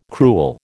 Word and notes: cruel: American English

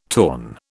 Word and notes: torn: British English

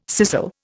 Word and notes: scissel: Indian

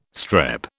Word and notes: strep: British English

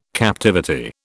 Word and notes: captivity: British English

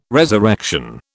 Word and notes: resurrection: British English

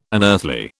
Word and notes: unearthly: British English

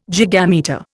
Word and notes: gigametre: Indian

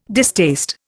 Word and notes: distaste: Indian

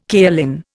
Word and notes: kaolin: Indian

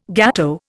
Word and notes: gateau: Indian

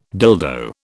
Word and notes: dildo: British English

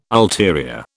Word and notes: ulterior: British English

